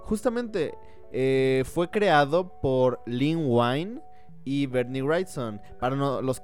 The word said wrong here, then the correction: Len Wein: que no sepan quién es Lin Wine, fue Len, Len Wine. Yo siempre lo pronuncio como Lin Wine.